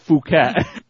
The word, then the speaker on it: Phuket: It's actually pronounced Phuket.